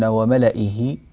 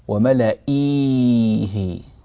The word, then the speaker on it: وملئه: نلاحظ أن كلمة ملئه كتبت بميم ثم لام ثم همزة وبعد الهمزة ياء ثم هاء، وملئيه، ولكن هذه الياء زائدة في الرسم لا تنطق في القراءة، وإنما نقرأها وملئه، فلا يوجد نهائيا أي نطق لهذه الياء لا في حال الوصل ولا في حال الوقف، حتى لو وقفنا نقول وملئه ولا نقول وملئيه، ما فيش هذه الياء غير موجودة نهائيا إلا في الكتابة، وهي زائدة في رسم المصحف.